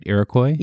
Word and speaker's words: Iroquois: Did I say that right, Iroquois? – Yes, you did.